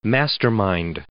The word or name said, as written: mastermind